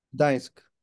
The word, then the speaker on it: Gdańsk: Gdańsk. Is that how you say it? – Gdańsk.